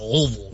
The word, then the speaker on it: Louisville: You gotta say it like, peanut butter stuck in the roof of your mouth. Louisville.